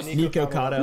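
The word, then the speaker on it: Nikocado: Well, maybe the difference is, like, I think we might be more likely to watch Report of the Week than Nikado Avocado, you know, like. Oh, yeah. Am I saying his name right? – Nico Cotto. – Nico Cotto Avocado.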